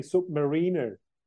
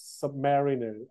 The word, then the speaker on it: Submariner: It's actually Submariner and not Submariner. I always say it wrongly. Actually, Bob Barth, when I went to see him in Panama City in Florida, he explained it to me. It's not Submariner.